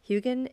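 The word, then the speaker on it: Huginn: But it's actually pronounced, let me think. – Munin? – Huggin and Munin.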